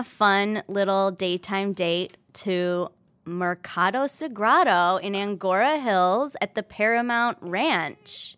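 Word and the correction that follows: Agoura Hills: Agora Hills? – Sure. Yeah. No N.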